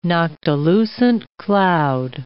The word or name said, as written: noctilucent cloud